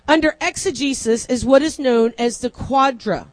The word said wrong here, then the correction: quadriga: Let me pronounce that correctly. It is called the quadriga. That's an R and an I. Quadriga.